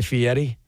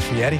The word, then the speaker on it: Fieri: I realize his name is pronounced Guy Fieri, but I feel weird saying that. I don't know why. I mean, it's spelled Fieri, and I say Fieri. I feel silly.